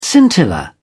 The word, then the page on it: scintilla: British English